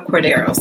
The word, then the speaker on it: Cordero: And today, my guest is an incredible, inspiring, empowering woman that I am honored to call friend and co-author, Alba Cadora Soto. Did I say that correctly? – Alba Cordero Soto.